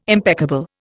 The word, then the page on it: impeccable: Indian